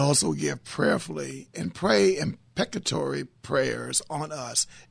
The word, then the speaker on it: imprecatory: PCH, what is the proper way to say that prayer? Imprecatory or impeccatory? – Imprecatory.